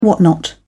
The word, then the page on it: whatnot: British English